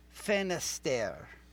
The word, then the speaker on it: Finisterre: So it is, it almost rhymes with Fred Astaire. So it's the name of the song is, I'm still not going to get this exactly right, but it's Wedding in Finisterre.